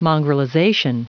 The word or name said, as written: mongrelization